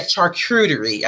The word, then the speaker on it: charcuterie: Yeah, charcuterie.